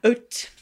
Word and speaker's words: haute: In 2016, you had a show called Beach Couture, an haute messe, which I love. – I like how you pronounce it. Haute.